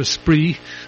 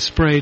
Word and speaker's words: Spree: About the only thing I know about the about Berlin is it's on the River Spree, or the River Spray, depending on how you care to pronounce it.